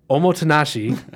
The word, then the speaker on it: omotenashi: the omotenashi, if I said it right.